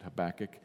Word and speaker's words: Habakkuk: there are different ways to pronounce his name, I pronounce it Habakkuk,